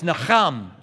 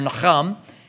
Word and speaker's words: Nacham: So this word is spelled N-A-C-H-A-M. The C-H is that sound. And so it's Naham.